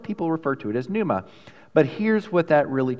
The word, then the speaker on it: pneuma: But that word spirit there is the word pneuma in Greek, or pneuma, depending on how you want to pronounce it. Do you want the old Greek or the new Greek?